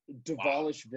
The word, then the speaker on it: Dvalishvili: The D is actually pronounced. It's Mehrab Dvalishvili. Wow.